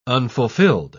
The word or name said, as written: unfulfilled